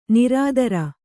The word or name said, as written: ನಿರುತ್ತರೆ